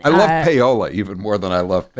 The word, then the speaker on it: paella: You were kind of saying it as it reads. You were pronouncing the L's. Cut two, please, Taylor. – Hey, Howie, it's paella. Not paella.